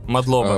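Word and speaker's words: Мадлоба (მადლობა): Ну, а есть, как считается, Мадлоба или Мадлоба? – Мадлоба. А, в, в, в грузинском нет ударений, в принципе.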